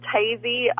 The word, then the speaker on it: Thesy: My great grandmother was called Thesy. Um, and the reason it's spelled T H E S Y is it's from uh, the root of Therese.